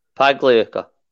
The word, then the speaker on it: Pagliuca: And Luca, Paul Juker. – Aye. Pagliuca. – Pagliuca.